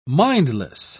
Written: mindless

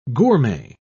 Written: gourmet

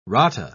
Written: rata

